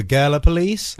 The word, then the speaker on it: Gallipolis: That's where he has Gal-EH- the word police. Gallipolis. – Like someone being like, "You're wearing white to this party? Oh, who are you? The Gallipolis?" – Oh, Gala.